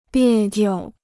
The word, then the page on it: 别扭: biè niu